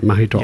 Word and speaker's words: Mahidol: I got the name right, Mahidol. – Yes.